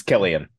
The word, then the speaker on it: Cillian: It's Killian.